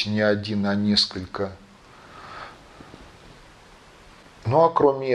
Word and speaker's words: Один: Ну, в общем, такая ситуация в некоторых источниках упоминается, и такие люди называются Один, что совпадает с именем древнескандинавского бога а может быть один. Но, ну, тем не менее, там, где я с этим делом сталкивался, там было ударение на первом «о».